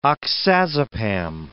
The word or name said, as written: oxazepam